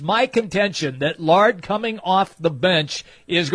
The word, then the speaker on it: Lard: John, it's my contention that Cameron Laird, or Lard, how do you pronounce it, just so I say it correctly? – Lard.